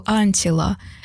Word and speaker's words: Антила: Я убрала сдвоенную «Т», добавила вторую «Л» и стала произносить на русский манер с ударением на второй слог «Антила». Спустя лет 10 или больше, впервые оказавшись в Хельсинки, напротив ЖД вокзала я увидела огромную вывеску «Антила».